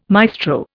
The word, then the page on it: maestro: Indian